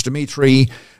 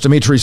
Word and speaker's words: Dimitri: Facebook Live, go to my profile page, Dimitri Vassilaris. Dimitri spelled with three I's, D-I-M-I-T-R-I.